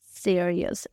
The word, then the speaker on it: serious: Se escribe así, S-E-R-I-O-U-S, e pero se pronuncia sí, sí como de sí, de sí de yes, sí, sí, y luego rius. Serious, serious. No sé, sino sí, ahí está la clave.